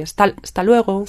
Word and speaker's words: hasta luego: The hasta is not pronounced completely. Hasta luego. – Sta. Comes out a bit like sta.